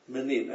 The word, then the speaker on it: Minim: És ez a, how do you say, Menim? – Menim, M-I-N-I-M, Menim.